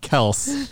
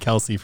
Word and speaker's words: Kelce: Our last name is pronounced Kels, but everyone said Kelce from the beginning and I never corrected anyone.